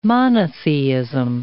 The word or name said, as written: monotheism